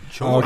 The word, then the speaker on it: Choi: And his name is spelled C-H-O-Y. – Oh, is it Choi? – Choi. Okay.